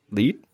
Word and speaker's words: lead: Barclays Blockchain DLT lead. – Yeah, something like that. – I think it's pronounced lead.